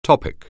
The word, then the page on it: topic: anglais